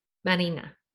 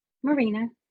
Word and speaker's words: Marina: How does your dad say your name? – Marina. – Okay. And what if you're like, hi, my name is, how do you say it? – Marina. – Okay. You're not like Marina.